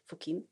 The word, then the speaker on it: Fokin: studerat under Fokin, är det så man uttalade? – Ja.